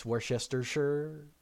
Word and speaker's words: Worcestershire: Because the Shire is silent, right? That's a thing. – Well, that's the sure part. – Worcestershire. Oh, it's Worcestershire. Worcestershire. Yep. two R sounds. I don't know. Worcestershire. Yeah. – Well, it's worst, which is the W-R-C-E-S-T.